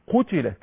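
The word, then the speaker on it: قُتِلَت: قتلت هكذا، قتلت لام خفيفة اهي، قتلت هكذا، يبقى اللام مشددة، اللام مفتوحة بس، قتلت.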